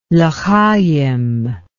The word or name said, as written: lchaim